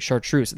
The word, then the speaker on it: chartreuse: Our next word is chartreuse, or you could make that S sound as Z, chartreuse. I shall say chartreuse. This one is spelled weird to me. It is C-H-A-R-T-R-E-U-S-E, chartreuse.